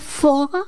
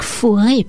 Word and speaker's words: Faure: Il s'agit de Jean-Baptiste Faure, à ne pas confondre avec Fauré, parce que ça s'écrit de la même manière, F-A-U-R-E. Celui-là, c'est Jean-Baptiste Faure, et non Gabriel Fauré.